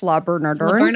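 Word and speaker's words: Le Bernardin: La Bernardin.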